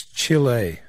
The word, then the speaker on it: Chile: You, as you've told me, it's correctly pronounced. I've been saying Chile. Chile. – It's Chile.